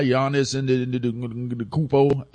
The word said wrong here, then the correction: Antetokounmpo: how you say his last name again? How y'all, anybody got it? Anybody got the proper? Say it again. – Giannis Antetokounmpo. I think I said it right.